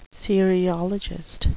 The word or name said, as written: cereologist